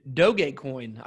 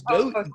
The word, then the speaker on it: Dogecoin: So can you share, what are all these like Ethereum and like Dogecoin? What are these weird names and how can I know what to invest in? – Dogecoin. I love that pronunciation. Yeah. – It's Doge.